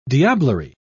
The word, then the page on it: diablerie: British English